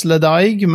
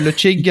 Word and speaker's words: Ledaig: Det är en, eh, den här som inte uttalas Ledaig, men som heter Luchig eller Lightcheck.